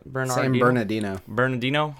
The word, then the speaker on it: San Bernardino: We're going to Exton, Pennsylvania, San Bernardino. Did I say that right? – San Bernardino.